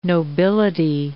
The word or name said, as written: nobility